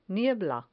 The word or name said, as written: niebla